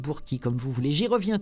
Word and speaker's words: Burke: Celui-ci s'appelle « L'arc-en-ciel de verre », du verre comme du verre, V-E-R-R-E. Il est signé James Lee Burkey, j'imagine qu'on doit prononcer comme ça. Moi je dirais Burke, ça, Burke, ça s'écrit B-U-R-K-E.